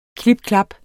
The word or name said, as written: klip-klap